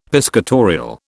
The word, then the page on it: piscatorial: British English